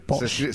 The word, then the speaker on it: pox: Es P-O-X, se escribe posh.